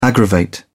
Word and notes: aggravate: British English

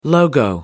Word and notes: logo: American English

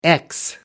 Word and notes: ex: American English

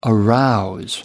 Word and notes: arouse: American English

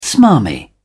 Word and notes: smarmy: British English

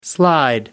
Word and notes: slide: American English

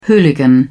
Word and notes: hooligan: British English

